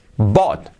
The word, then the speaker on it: bought: I wouldn't have, I wouldn't have, cuidado have, que no es have, I wouldn't have con la H suave, have bought. La pronunciación de bought, igual que thought, recordar el pasado de pensar, el participio, pues aquí bought.